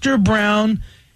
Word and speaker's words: Brown: Hi, my name is Mr. Brown. And by the way, the Brown is spelled just like the color.